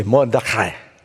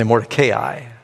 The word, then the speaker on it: Mordecai: If you're Jewish, you say Mordecai. You don't have to say it like that. And some people say Mordecai. I've said it like that. I've even heard I just yesterday heard a Baptist pastor saying it that way.